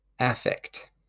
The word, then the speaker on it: affect: And in this case, it is pronounced as affect.